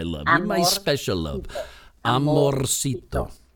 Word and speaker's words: amorcito: But you say it quickly, right? You don't have to go so heavy. Like, amorcito. – Yeah. – Did I say it right? – That's right. But now you say it quickly, and it's almost like you don't even roll. You go, ay, qué bueno, amorcito. And it just comes out like this little thing.